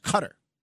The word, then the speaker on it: Qatar: It's not pronounced Qatar. It's pronounced Qatar.